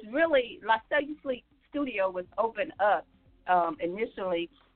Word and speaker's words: La Cellu Sleek: It's La Cellu Sleek Studio. La Cellu Sleek. – And that's spelled LaCellu and then Sleek, SLEEK.